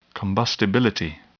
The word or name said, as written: combustibility